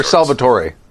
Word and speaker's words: Salvatore: Yeah, but is it Salvatore or Salvatore? – Salvatore. – He doesn't pronounce the E? – I've heard him introduce himself in an interview, so it's Salvatore.